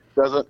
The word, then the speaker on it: Struble: Struble. Doesn't rhyme with trouble.